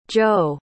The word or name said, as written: zhou